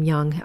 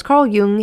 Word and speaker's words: Jung: So spring forward to the 20th century, and it was Carl, I know you don't pronounce his name Jung, and it was Carl Jung in 1921 who took the four humors of Hippocrates and broke them down into personality types.